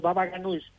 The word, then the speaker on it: baba ganoush: También el famoso estofado de berenjena, que a mí me gusta mucho, el baba ganoush. No sé si lo dije bien, pero ese entiendo que también mucha gente lo pide y que les encanta, ¿no? – Exactamente. Y bien pronunciado, el baba ganoush.